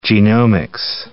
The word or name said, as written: genomics